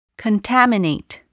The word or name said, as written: contaminate